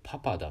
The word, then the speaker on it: 母: さあ、これは一体どういうことかというとですね、何もお母さんが性転換したとかねそういう話ではないんです、えー、日本語で母っていうのは昔の日本語の発音でパパだったと